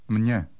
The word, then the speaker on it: Mně: Mně. – Number 17. – Mně. – There's no difference in pronunciation between number 16 and number 17.